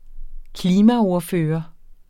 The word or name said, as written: klimaordfører